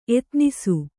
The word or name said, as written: ಎತ್ನಿಸು